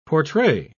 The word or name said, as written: portray